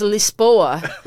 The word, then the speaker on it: Lisboa: You know the Portuguese, you know, pronunciation is Lisboa.